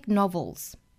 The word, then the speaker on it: novel: aren't epic novels， epic novels， epic 这里面的科的发音我们可以做失去爆破处理哈， epic novels 憋一下不爆破出来， epic novels epic novels。好， novel 这个词呢尾部是一个舌侧音 novel，单词尾部舌侧音舌尖抵上齿音 novel novels epic novelsEpic novels，后面 some short stories。